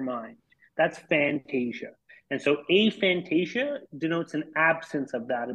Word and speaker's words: aphantasia: So for the record, it's probably somewhere between aphantasia and aphantasia. It's more like a tomato in terms of the pronunciation on that.